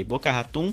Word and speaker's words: Boca Raton: É, que ele falou que a Rockstar tem alugado casas na, na, região de Boca Raton, é assim que pronuncia, DJ? Boca Raton. Boca Raton, tá aqui, aqui inclusive aqui, do lado aqui.